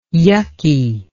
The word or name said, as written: yucky